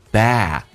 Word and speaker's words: bad: Your bad memories. Bad 字的 D is silent， D 不发音，因为后面是以辅音开头，这叫略音。